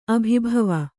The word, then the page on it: ಅಭಿಭವ: abhibhava; Kannada